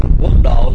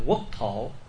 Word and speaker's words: quốc thổ: Quốc độ là mình đọc là quốc độ, chứ đúng chữ chúng ta phải đọc là quốc thổ.